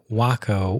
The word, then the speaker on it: Waco: And, you know, is it pronounced Waco? Is it Waco? Well, it's Waco.